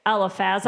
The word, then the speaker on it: Eliphaz: Eliphaz.